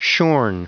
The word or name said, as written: shorn